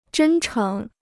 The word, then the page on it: 真诚: zhēn chéng